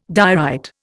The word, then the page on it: diorite: Indian